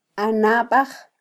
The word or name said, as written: anbaj